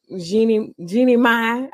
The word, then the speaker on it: Jeannie Mai: So Jeezy and Jeannie Mai, I can't ever say her name right. – You said it, girl. You said it right.